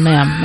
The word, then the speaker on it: ma'am: Not mom, ma'am. Ma'am that rhymes with ham.